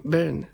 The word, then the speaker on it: Byrne: Kako se izgovara zapravo David Byrne? – Byrne. Byrne. Ja mislim Byrne. Da je David Dobro. – Byrne, da.